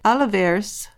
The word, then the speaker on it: Olivares: And we've learned that a 28-year-old Miguel Enrique Olivares has been charged with first-degree murder in her death. – O-L-I-V-A-R-E-S? – Yep.